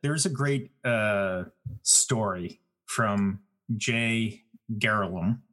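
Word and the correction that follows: Glerum: I always pronounce his last name improperly. – Glarum.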